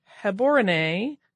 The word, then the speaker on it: Gaborone: but it's a B-A-T, not a B-O-T. Also, the capital city of Gaborone is pronounced more like Heborene with a guttural G and the accent on the final E.